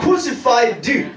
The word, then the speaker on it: crucified: I have been crucified. So you have to pronounce that D very well.